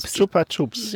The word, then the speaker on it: Chupa Chups: Chupa Chups.